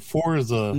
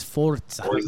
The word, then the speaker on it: Forza: I know a lot of people are like, Forza. – No, it's Forza. – No, it's like pizza. – Like pizza. It's like Forza.